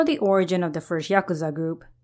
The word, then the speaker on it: Yakuza: These three numbers, 8, 9, 3, when read in a specific manner, are pronounced Yakuza.